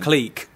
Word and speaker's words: clique: It's pronounced click. – Okay, click.